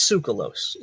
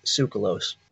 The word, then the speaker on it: Tsoukalos: And most people still say his name wrong because of how it's spelled. It's spelled like T-S-O-U-K-O-L-A-O-S or something to that effect, like Tsoukalos or Tsoukalos, something weird, right? It's Tsoukalos. It's pronounced Tsoukalos.